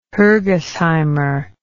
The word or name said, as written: Hergesheimer